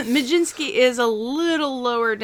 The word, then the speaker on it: Mlodzinski: That is M-L-O-D-Z-I-N-S-K-I. Of course pronounced Majinski.